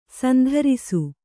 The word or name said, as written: ಶಾಂತರಸ